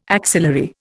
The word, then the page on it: axillary: Indian